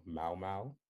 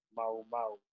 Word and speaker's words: Mau Mau: Did I pronounce that correctly? – Yep. – All right, cool. – Yeah. You have to separate it like Mau Mau. – Oh, Mau. Oh, there's a pause. Mau Mau.